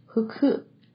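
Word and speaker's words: ふく: 福代表福气，在日语里的读音是 huku huku huku，而猫头鹰 hukuro 刚好就含有这个声音，所以下一次有人送你猫头鹰的时候，你就知道是什么意思了。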